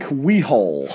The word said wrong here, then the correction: Whaley: Frank Whaley. It's just Whaley.